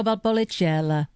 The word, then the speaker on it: Valpolicella: It is the Lonardi Ripasso Valpolicella.